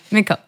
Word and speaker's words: Mikkel: It's pronounced Mikkel. – Ah, okay. We did kind of pronounce it wrong. Mikkel, okay.